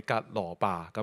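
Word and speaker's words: 革羅巴: 经文里面呢他讲一个叫做有一个门徒呢，其中一个门徒呢叫格留巴，有學者形容咧呢個嘅格留巴嘅呢個嘅名字嘅讀音咧，與約翰福音嘅 19:25 呢個格羅巴咧，唔係格羅伯啊，係格羅巴咁樣嚇。個讀音咧，嗱你睇下啲讀音幾似啊，啲讀音好相似，所以咧有人認為咧呢兩個咧係同一個人。嗱，如果既然係同一個人嘅時候咧，喺約翰福音 19:25 咧，呢個格羅巴呢個嘅佢嘅妻子咧就係瑪利亞。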